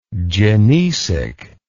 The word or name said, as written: gynecic